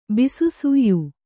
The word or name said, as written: ಬಿಸುಸುಯ್